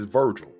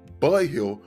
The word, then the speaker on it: Virgil: In Spanish, whenever, especially older Spanish people, whenever they see my name and they say it, because in Spanish, the V is pronounced like a B and the G is pronounced like an H. So it's Virgil.